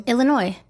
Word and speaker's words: Illinois: Do you know how to say Illinois? Say Illinois. – Illinois. – Yeah, okay. You said that right.